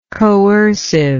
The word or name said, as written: coerciveness